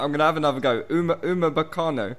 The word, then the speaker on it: Upamecano: Is it Upamecano? – Upamecano.